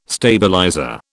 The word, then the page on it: stabilizer: British English